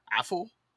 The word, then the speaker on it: Afful: And then it looks like his last name is spelled, it's not awful, but it's Affle. So A-F-F-U-L.